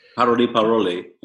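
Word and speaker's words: Paroles, paroles: Parole, Parole, yeah. – Ouais. – Sorry, my English, my French pronunciation is appalling. – But we would say in French, parole, parole.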